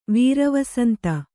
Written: ವೀರವಸಂತ

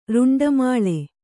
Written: ರುಂಡಮಾಲೆ

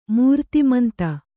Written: ಮೂರ್ತಿಮಂತ

